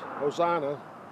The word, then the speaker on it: Hosanna: Well, I don't know if I've pronounced this properly, but I believe Hosanna is a Greek rendering of a Hebrew word, Hosanne, I think. Hosanne. The Greek is Hosanna.